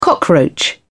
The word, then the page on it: cockroach: British English